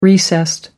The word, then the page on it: recessed: American English